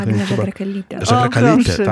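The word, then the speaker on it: Agnė Žagrakalytė: i tłumaczka Magda Nowakowska oraz Agne Żagra-Kalytę, nie wiem, jak się akcentuje. – Agne Żagra-Kalytę,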